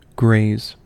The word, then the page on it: graze: American English